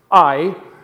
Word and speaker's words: Ai: And it's actually, believe it or not, even though there's only two letters there, it's actually a two-syllable word. It's a heavy breathing, ha, Ai.